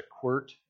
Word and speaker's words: quirt: One of the things that we used some, the little bit of ranch work that I did, we used a quirt. Any of you familiar with the quirt? It's spelled funny. It's quit with an R in it.